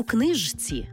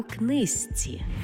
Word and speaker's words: книжці: Пишемо «жці», а вимовляємо «зці». Наприклад, не «подружці», а «подружці». Не «знижці», а «знизці». І не «у книжці», а «у книзці».